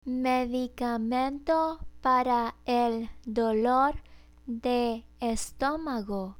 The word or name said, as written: medicamento para el dolor de estomago